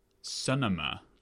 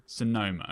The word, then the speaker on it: Sonoma: Sonoma. – No, macOS Sonoma, you're right. – Sonoma, okay.